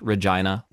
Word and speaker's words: Regina: It's pronounced Regina, like vagina.